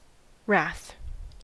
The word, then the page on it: wrath: American English